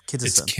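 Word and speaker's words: Kinison: Kinnison.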